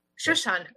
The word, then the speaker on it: Shushan: Shushan, okay.